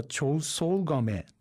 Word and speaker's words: 長宗我部: 当時は長宗我部と読んでいた可能性が非常に高いと考えています。